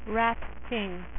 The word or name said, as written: rat king